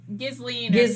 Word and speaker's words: Ghislaine: It almost sounds like Eileen. Ghislaine.